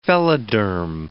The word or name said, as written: phelloderm